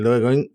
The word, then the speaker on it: Dogecoin: A ver, eh, ¿qué pasaba, qué estaba pasando con el Dogecoin y las expectativas que había del, del Dogecoin? Que por cierto es la primera vez que veo cómo se pronuncia, que se pronuncia Dogecoin. Yo pensaba que era Dogecoin, pero no es Doge.